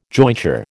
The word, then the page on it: jointure: American English